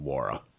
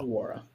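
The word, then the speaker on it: Nwora: And the N is silent, so it is Jordan Wara. So Wara. – Okay. Wara.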